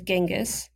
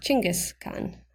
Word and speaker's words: Chinggis Khan: Internationally known as Genghis, in Mongolia it is pronounced Chinggis Khan,